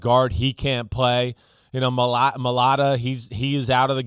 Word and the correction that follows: Mailata: I know I butchered his last name. So Jordan Mailata. – Mailata. Okay, I was close enough.